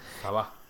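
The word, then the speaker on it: Sabah: Pero más, me da más rabia el saber que el ministro de, el lugar se llama Saba, eh, no sé si lo dije bien, en Malasia, él se llama Musa Aman. – Saba. – Saba.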